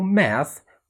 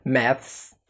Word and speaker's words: maths: في أمريكا بيقولوا math، وفي إنجلترا تقدر تقول math زي أمريكا بالظبط، وفي ناس بتفضل إنها تقول math math بزيادة S ونطقها بيكون خفيف جدا كده ممكن ما تلاحظوش math. ففي إنجلترا تقدر تقول math وتقدر تقول maths، إنما في أمريكا بيفضلوا يقولوا math.